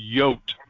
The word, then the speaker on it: Jot: is the letter J in the German language is Jot. – Jot. – Okay, so the German J is pronounced like a Y.